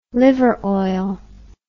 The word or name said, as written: liver oil